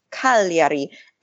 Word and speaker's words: Cagliari: But then he went to Cagliari. Is that, I can't pronounce anything in Italian. I pronounce it in Spanish. – Cagliari.